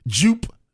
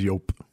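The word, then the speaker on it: Joop!: Jupe. – Did anybody? – Yope. Did anyone wear jupe? – Yope. – Was it yope? – It was yope. – Not jupe.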